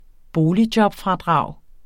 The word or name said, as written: boligjobfradrag